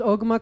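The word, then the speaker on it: Ogemaw: You can go to the Ogama. Am I saying that right? – Yep, it's Ogama County Herald.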